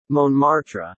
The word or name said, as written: Montmartre